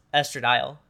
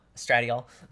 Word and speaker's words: estradiol: Thank you to um, how I was taking my est- estradiol estradiol. I say it wrong. – Uh, uh, so. It's both are right. Any yeah. way you want to say it is correct.